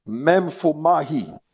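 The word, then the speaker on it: memphomai (μέμφομαι): Now, that phrase, or those two words, finding fault, is from one Greek word, and is the word memphomai. I'll spell it. Memphomai is M-E-M-P-H-O-M-A-I. Memphomai. Memphomai.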